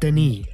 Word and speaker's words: Denis: Saint Denis. – Yeah, Denis. Saint okay. – Denis. – What? – Denis. – All right. I guess the French don't pronounce a lot of their letters.